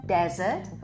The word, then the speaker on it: desert: No i tutaj jest właśnie te przejście tego akcentu, że wyraz pustynia akcentujemy na pierwszą sylabę, wymawiamy desert, a wyraz deser wymawiamy z akcentem na drugą sylabę, czyli dessert.